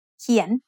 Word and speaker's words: เขียน: Kien is said with a rising tone because it has a high class consonant and a live syllable ending. Let's write kien together.